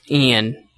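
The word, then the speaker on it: Iain: It's called I'm Thinking of Ending Things by Ian Reid, and it's I-A-I-N. So I think it's Ian. I think that's how you say it.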